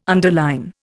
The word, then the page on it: underline: Indian